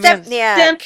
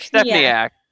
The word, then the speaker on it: Stempniak: Step what's how do you say his last name? Stempniak. Stempniak.